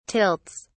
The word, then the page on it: tilts: British English